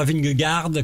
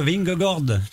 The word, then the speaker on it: Vingegaard: Je sais pas comment en fait, le A-A se prononce O, en danois, c'est comme ça. – Ah bon? – Ce qui fait qu'il ne faut pas dire Vingegaard, comme disent les journalistes, c'est le vélo, ça, hein. Mais il faut dire Vingegaard parce que AA c'est O.